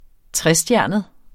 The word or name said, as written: trestjernet